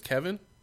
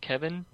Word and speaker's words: Kevan: But with you said his name was Kevin? – I've heard him pronounce it Kevin, but it's spelled like K-E-V-A-N, so it looks more like Kevan.